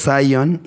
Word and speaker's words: Zion: Según yo es Zion y, Zion? – y Lennox.